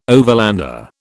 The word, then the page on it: overlander: British English